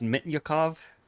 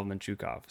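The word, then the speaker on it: Mintyukov: Also, little bone to pick, it's not pronounced Mitnyakov. Like, please, someone get this over to the Ducks broadcast desk. Like, please, because it just hurts my ears every time I hear it. There's just no possible way it's pronounced that way in the mother tongue of Pavel Minchukov.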